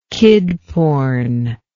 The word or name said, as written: kid porn